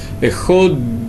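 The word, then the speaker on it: Эход (אחד): Принято, когда мы читаем слово «эхат», на букве «далит» немножечко растянуть эту букву. «Эход», тянуть ее настолько, насколько это можно, но так, чтобы произношение не испортилось.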